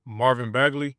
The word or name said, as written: Marvin Bagley III